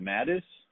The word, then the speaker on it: Matus: Is it Matus? – It is Matus.